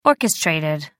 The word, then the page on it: orchestrated: American English